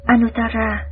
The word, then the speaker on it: Anuttara: Chữ A Nậu Đa La, nguyên âm Ấn Độ đọc là Anuttara.